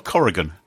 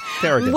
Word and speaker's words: Carrigan: Is it Carrigan or Corrigan? – Carrigan, with an A, yeah. – Carrigan.